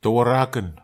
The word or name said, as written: tauaraken